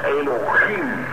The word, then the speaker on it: Elohim: Se pronuncia Elohim, porque la H suena como J.